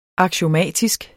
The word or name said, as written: aksiomatisk